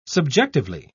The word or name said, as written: subjectively